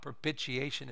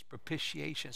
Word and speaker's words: propitiation: It's not propitiation, it's propitiation.